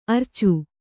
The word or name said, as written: ಅರ್ಚೆ